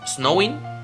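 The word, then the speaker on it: Samhain: La noche del 31 de octubre, un festival conocido como Samaín, pronunciado Snowing, que significa fin del verano.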